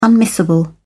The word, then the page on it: unmissable: British English